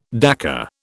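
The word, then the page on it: décor: British English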